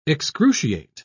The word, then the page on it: excruciate: British English